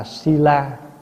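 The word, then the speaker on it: śīla: Giới tiếng Phạn đọc là sila, mình âm là thi la.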